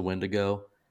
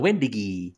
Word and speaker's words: Wendigo: We do have Slosson, or we have somebody who mispronounces the Wendigo, the Wendigi.